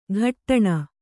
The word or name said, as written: ಘಟ್ಟಣ